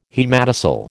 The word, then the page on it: haematocele: American English